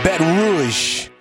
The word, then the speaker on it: Baton Rouge: That's how they say it. Baton Rouge.